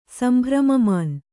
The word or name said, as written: ಸಂಭ್ರಮಮಾನ್